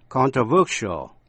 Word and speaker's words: controversial: Khi thành tính từ controversial, controversial thì dấu nhấn rơi vào âm thứ ba.